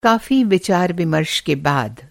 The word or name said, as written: विचार-विमर्श